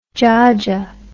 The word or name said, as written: djaja